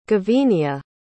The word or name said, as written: gavenia